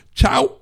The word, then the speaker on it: Shaw: en el 605 Chau Avenue. Chau se pronuncia S-H-A-W, Avenue,